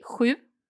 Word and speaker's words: sju: And you said perfectly sju.